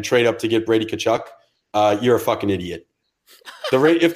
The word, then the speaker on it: Tkachuk: This is Brady Tkachuk. – Nope. – Tkachuk. – Tkachuk. – Tkachuk. – The T almost doesn't exist.